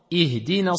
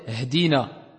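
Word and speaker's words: Ihdina (اهدنا): Mais quand il est au début, on doit ici le prononcer. Wadih? Exemple. Ihdina sirata. Ihdina sirai. D'accord? On va pas dire hdina.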